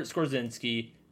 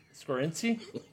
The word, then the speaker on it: Skoronski: And Peter Skorzinski versus Garrett Nelson. – Skoronski? – Skoronski.